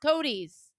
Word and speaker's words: coatis: It's pronounced Cody's.